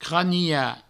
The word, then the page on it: cranilhar: craniha